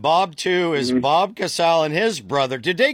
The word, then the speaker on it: Casale: Did they pronounce it Casal? – No. Listen, Bob won is my cousin, Bob Mothersbaugh. Bob two is Bob Casal. And Jerry Casale is.